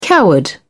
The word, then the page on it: coward: British English